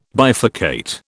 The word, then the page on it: bifurcate: British English